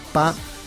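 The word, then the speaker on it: pa (八): La explicación es por qué el 8 en chino se dice pa, muy similar a la pronunciación de la palabra fa, que significa riqueza. Y en cuanto al 9,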